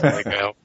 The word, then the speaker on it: Lego: The term, if you're in England, I don't know about America, is Lego. Oh, it's in America too. I've always said it wrong. – I have lots of arguments with people about it. – It's Lego. See, you wrecked it. We were just doing it right. We wrecked it. – I know I say it wrong, but I have always done so, and so it's one that I just admit to, but have not stopped.